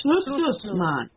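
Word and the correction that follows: Schlusnus: No, I don't think there's a man on the end. I think it's just Schlussnuss. – Oh, I somehow added the man on the end.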